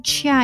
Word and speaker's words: chia: 그래서 chia, ch 사운드기 때문에 chia 이렇게 발음하셔야 돼요.